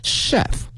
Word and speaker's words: chef: This season focuses on areas，它关注的这个领域呢是呢 such as kitchenware Kitchen，我们学过的是厨房，后面加一个 ware， kitchenware 表示说呢厨具，然后呢小吃是 snack， snack，然后呢是宴会哈 banquet， banquet， banquet， chef 厨师这个词大家小心了，它的拼写呢是 chef，但是它的发音呢是 chef 啊 chef， and dessert。